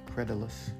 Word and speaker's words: credulous: You said credulous. That D is pronounced like a J.